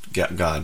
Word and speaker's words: Gad: Just to be clear, it's Gad, G-A-D, not God. It's the children of God. It's pronounced God, but it's spelled like Gad.